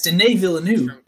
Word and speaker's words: Denis Villeneuve: Because I think, is it not Denis Villeneuve? – Yeah, I think so. It's like French. – Yeah, it's Denis Villeneuve.